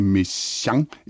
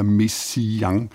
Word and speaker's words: Messiaen: Det andet er så, om man skal høre to eller tre stavelser udtalt, som du skriver, Henrik, er det Messiaen eller Messiaen? Og her på den lille redaktion, Spørg om alt-redaktion, der har vi lyttet til forskellige indfødte franskmænd, der udtaler navnet for at belære andre, hvordan man gør. Og det lyder nærmest som en mellemting af to og tre stavelser.